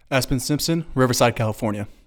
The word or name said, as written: Espn Simpson